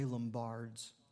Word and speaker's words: Lombards: Say Lombards. I think that's the right way to say it. That's the way I say it anyway.